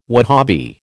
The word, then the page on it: Wahabi: American English